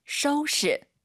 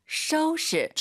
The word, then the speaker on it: 收拾: 收拾. – 한 번 더. – 收拾. – 지금 오유 발음이 연달아 두 번 있죠? 또우, 쇼우예요. 절대 또쇼 아닙니다, 여러분. 전체 문장 들어보겠습니다.